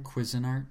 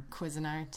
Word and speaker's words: Cuisinart: Cuisinart. – Cuisinart.